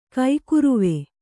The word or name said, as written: ಕೈಕುರುವೆ